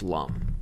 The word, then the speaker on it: Lum: Apparently, my research is telling me that it's pronounced Lum, but you can continue. – Is it Lum? I assume it was Loom.